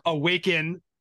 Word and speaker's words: AWAKN: And I've been around this world, you know, and they have these funny names like Field Trip Psychedelics Incorporated or Awaken, but spelled like capital AWAKN.